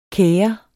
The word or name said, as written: kere